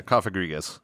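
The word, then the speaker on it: Cofagrigus: Kofagrigus? – Yeah, Kofagrigus.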